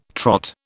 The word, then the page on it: Trot: British English